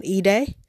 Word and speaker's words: Idai: Just last week, Cyclone Idai, I think that's how you say it. It's I-D-A-I, so I think that's Idai, hit Mozambique.